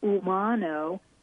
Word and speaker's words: Umano: And then my last name is Umano, UMANO.